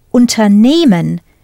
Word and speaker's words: unternehmen: Listen to where I put the stress and tell me whether unternehmen and übernehmen are separable verbs or not. Unternehmen, übernehmen. As the stress is not on the first syllable, the first part, they do not separate.